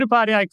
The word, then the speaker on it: Upadhyay: Sid Upadhyay, co-founder and CEO at WizeHire.